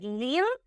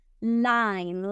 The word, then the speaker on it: line: Entonces vamos a pronunciar la I como I. En lugar de li, decir lean, vamos a decir line, line.